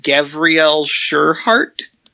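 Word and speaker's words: Gavriel Sureheart: So, we've got all that coming out, and now we get the new Lord Celestin Gavriel Sherhart. I'm pretty sure that's how you say his name. – I believe so.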